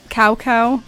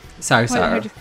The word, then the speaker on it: Cao Cao: And they say Cow Cow. It's not how you say it. – Cao Cao.